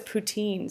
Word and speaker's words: poutine: Well, I'm from Montreal, so I love poutine, and that's how you pronounce it. – But it was poutine, so that's good to know.